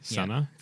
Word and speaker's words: Sanne: So Sana Bakar, so S-A-N-N-E, Sana. Yep. That's a pronunciation with it.